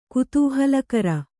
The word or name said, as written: ಕುತೂಹಲಕಾರಿ